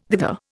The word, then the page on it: thither: Indian